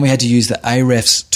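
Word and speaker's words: Ahrefs: So AREFS was a turning point for us, which is spelled A-H space R-E-F-S, where we could look at, you know, we had to specialize in a sector.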